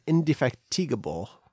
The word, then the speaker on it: indefatigable: The Astasa opinion of the Sosa was that they were slow, dense, deceitful, and indefatigable. How do you pronounce indefatigable? – Indefatigable. – Indefatigable. – Yep.